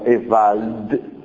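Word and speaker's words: Ewald: Há um detalhe curioso, característica do discurso jurídico, em que um autor francês chamado François Evald, sim, com D pronunciado como uma exceção,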